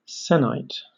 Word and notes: sennight: British English

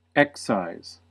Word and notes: excise: American English

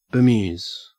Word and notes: bemuse: Australian English

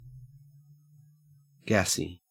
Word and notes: gassy: Australian English

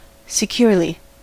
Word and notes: securely: American English

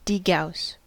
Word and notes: degauss: American English

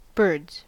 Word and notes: birds: American English

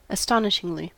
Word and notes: astonishingly: American English

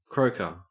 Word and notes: croaker: Australian English

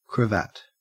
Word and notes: cravat: Australian English

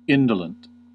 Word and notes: indolent: American English

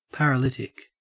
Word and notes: paralytic: Australian English

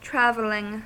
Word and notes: traveling: American English

